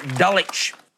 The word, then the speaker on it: Dulwich: Claire in Dulwich in South Australia. It's a silent W for anyone playing at home.